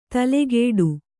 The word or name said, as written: ತಲೆಗೆಡು